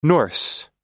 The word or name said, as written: Norse